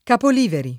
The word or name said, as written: Capoliveri